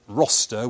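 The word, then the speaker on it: Rocester: The first of which, the JCB Academy at Roster, which is spelt like Rochester without the H, in Staffordshire.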